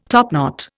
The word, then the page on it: topknot: Indian